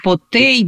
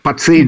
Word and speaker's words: Потий: Два епископа, Кирилл Терлецкий и Патий Потий, по-разному пишется его фамилия, но вот сейчас я вижу общепринятое Потий. Потий, Потей, да, по-разному. – Да, Пацей даже иногда.